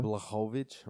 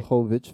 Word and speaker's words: Blachowicz: After having stood up last night to watch that the Izzy fight, the Israel Adesanya fight against John Popovich, Polish power. – Blachowicz, right? – Blachowicz, facts. Sorry, I mispronounced it.